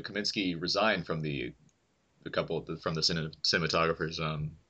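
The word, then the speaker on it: Kamiński: Is that how you say his name? – Yes, Kaminsky. Yeah.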